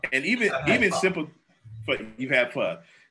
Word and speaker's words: pho: Vietnamese is a soup called pho, P-H-O. Vietnamese soup. – Oh, yes, I have pho. You said pho. – Yeah, well, it's pho. It's pronounced pho, even though it's spelled P-H-O.